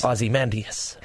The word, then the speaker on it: Ozymandias: Ozymandias.